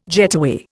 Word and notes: getaway: Indian

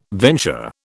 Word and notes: venture: British English

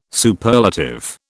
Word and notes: Superlative: British English

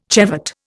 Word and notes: chevet: Indian

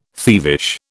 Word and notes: thievish: British English

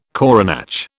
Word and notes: coronach: British English

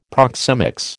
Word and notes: proxemics: American English

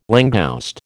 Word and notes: langouste: American English

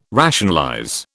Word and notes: rationalize: British English

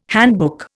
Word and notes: handbook: Indian

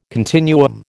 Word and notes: continuum: American English

